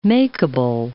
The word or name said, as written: makeable